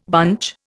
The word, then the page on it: bunch: Indian